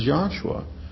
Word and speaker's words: Joshua: and that the shortened form Joshua is not to be pronounced as Yahweh Hoshea or Yahweh Hushua or Yahushua or Yahushua, but rather it is simply to be pronounced as Joshua.